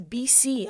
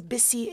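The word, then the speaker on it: Bisi: when people would say my name is BC, I'm like, no, my name is Bissi. It's not B and C, it's B-I-S-I.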